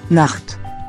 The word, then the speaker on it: Nacht: The word for night sounds a lot like knocked. Like, he knocked on the door. It's spelled N-A-C-H-T.